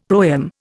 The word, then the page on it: proem: Indian